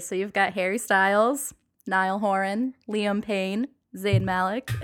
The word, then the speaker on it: Niall: Niall. Yeah. Okay. Niall. And they. So you have to say it like with an Irish accent. Niall.